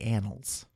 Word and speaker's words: Annals: No, the Annals. – Oh, okay. – Like years. – Not like butts.